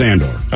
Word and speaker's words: Andor: I believe it's Andor. – Okay, all right. – I might be saying it wrong. – Andor, it looks like Candor, it's more in a city.